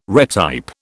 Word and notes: retype: British English

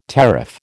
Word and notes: tariff: American English

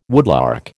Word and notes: woodlark: American English

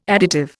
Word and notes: additive: Indian

